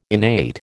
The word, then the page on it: innate: American English